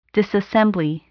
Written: disassembly

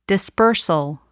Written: dispersal

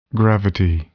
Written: gravity

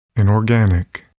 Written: inorganic

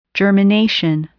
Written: germination

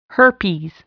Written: herpes

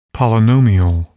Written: polynomial

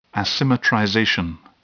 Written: asymmetrization

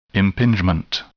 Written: impingement

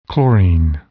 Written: chlorine

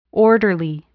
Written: orderly

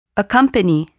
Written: accompany

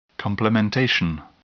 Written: complementation

